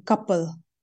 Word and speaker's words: Kapil: I can't pronounce my own first name, but my father's side would pronounce the last name couple, but Kapil.